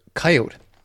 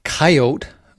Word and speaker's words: coyote: In the book Coyote America, author Dan Flores talks kind of in the opening parts of the book about the different ways of pronouncing the word coyote. So it could be pronounced coyote, like if you think of it starting with the letter K, coyote, or coyote.